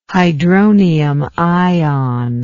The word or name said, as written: hydronium ion